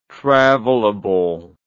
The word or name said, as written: travelable